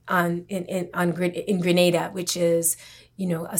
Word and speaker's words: Grenada: Well, I, Grenada, because um, I and I make the distinction in pronunciation because I think of Gra- Granada as Granada in Spain,